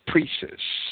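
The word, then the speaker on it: précis: And the protocols are certainly not a mere summary or precis of the dialogues that may be pronounced as precis, I believe, since the material they share in common only represents a small portion of the body of the protocols.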